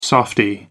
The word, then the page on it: softie: American English